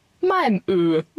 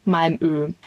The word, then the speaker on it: Malmö: Und dann haben wir einen Tagesausflug nach Schweden gemacht, nämlich nach Malmö, wie es wirklich ausgesprochen wird. Wir haben immer natürlich Malmö gesagt, das ist schön eingedeutscht, aber es heißt theoretisch Malmö.